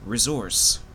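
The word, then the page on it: resource: Canadian English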